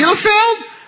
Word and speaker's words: Bielefeld: Bielefeld. Is that correct? Did I say Bielefeld? – Правильно. – I actually said it correctly.